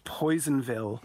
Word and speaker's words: Personville: it's Personville, pronounced Poisonville.